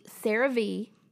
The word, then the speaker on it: CeraVe: So a couple of people actually recommended the CeraVe.